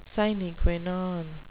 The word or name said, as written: sine qua non